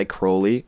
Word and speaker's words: Crowley: And I say Crowley, and it's funny because, my wife has a big thing for crows. But not because of that. It's because that's actually how you pronounce name. Even Ozzy in you know, Mr. Crowley, he says it wrong.